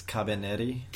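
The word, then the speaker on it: Kabaneri: It's Cabanera. – I think it's Cabaneri.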